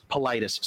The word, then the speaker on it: Paulides: So, missing 411 is a story set by a guy named David Paulides, who also Paulides, is that how you say it? – Paulides.